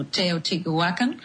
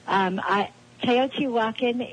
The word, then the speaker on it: Teotihuacan: But Michelle, I want to ask you, I know you take people on a spiritual journey to Teotihuacan, as I pronounce that correct. – Yes.